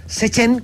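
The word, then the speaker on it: CCHEN: Muchísimas gracias al doctor Enrique Mejías Barrios, investigador en temas apícolas de la Comisión Chilena de Energía Nuclear, SECHEN, como me dijeron que se pronunciaba para que no me reten.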